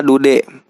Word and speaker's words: Dude: Suatu hari, Suar bertemu dengan seorang lelaki bernama Dude. Dibacanya jangan bahasa Inggris ya, teman-teman. Dibacanya dengan bahasa Indonesia aja. Dengan cara du de.